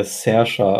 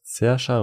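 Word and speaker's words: Saoirse: Das wird äh, Sersha ausgesprochen. – Sersha? – Ja. Mm. Sersha Ronan, Adrian Brody, Ruth Wilson, Reese Sharesmith, Harris Dickinson, Charlie Cooper, Pippa, Bennett Warner und so weiter und so fort.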